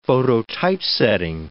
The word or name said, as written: phototypesetting